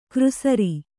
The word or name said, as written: ಕೃಸರಿ